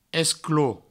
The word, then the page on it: esclop: provençal